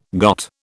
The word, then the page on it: got: British English